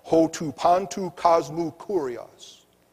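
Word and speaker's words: Hotu Pantu Cosmu Kurios (ὁ τοῦ παντὸς κόσμου κύριος): He had himself ascribed as Hotu Pantu Cosmu Kurios. Did I say that right? Okay. Those of you who are in Greek 3, did I say that right? – Yes.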